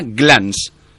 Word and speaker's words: Glance: Por ejemplo, esta se llama Glance. G-L-A-N-C-E eh, lo pronuncio porque vosotros no sois nativos ingleses.